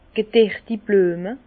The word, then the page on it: gederti Blüeme: Bas Rhin; Herrlisheim